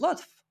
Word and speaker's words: Lutf: So Live Up to Freedom came from a four-letter word, L-U-T-F, which is abbreviation. You would say Live Up to Freedom, abbreviation L-U-T-F. But originally, L-U-T-F, if you try to pronounce it, will sound Lutf.